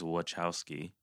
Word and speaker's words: Wachowski: According to interviews done by Lana, the pronunciation of the last name is Wachowski. – Oh, okay. – It's Wachowski, it's Americanized pronunciation. So that's how she pronounce it. But isn't a CH? – So it's Wachowski. – It's Wachowski. That's how they say it.